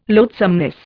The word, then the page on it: loathsomeness: Indian